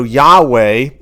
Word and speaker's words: Yahweh: It is pronounced, you know, Yahweh, but it's built on the word for I am.